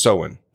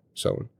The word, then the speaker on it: Samhain: So Halloween origins date back to the ancient Celtic festival of Samhain, pronounced Samhain. Sorry, Samhain.